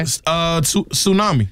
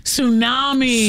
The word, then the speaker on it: tsunami: This has a silent letter in it. – Okay. – Tsunami. – Tsunami.